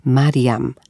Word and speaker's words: Marijam: Ime Mirjam so izgovarjali kot Marijam, kar je bilo lahko spravljati v zvezo z deblom, ki pomeni gospa.